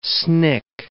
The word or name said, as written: snick